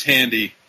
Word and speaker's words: Handy: It is Handy.